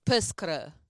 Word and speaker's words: pescre: We say pescre. – Pescre. Wow.